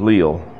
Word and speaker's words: Liele: George Lyle, L-I-E-L-E. Some people pronounce it as Lyle.